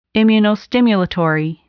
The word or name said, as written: immunostimulatory